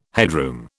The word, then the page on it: headroom: British English